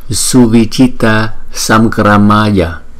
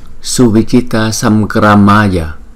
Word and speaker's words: Suvichitta Samgramaya: Danh hiệu Đấu Chiến Thắng Phật chúng ta phải phát âm là Suvichitta Samgramaya, Suvichitta Samgramaya. Chữ A, hai chữ A này có dấu gạch ngang trên đầu thì chúng ta đọc dài một chút so với các chữ A còn lại.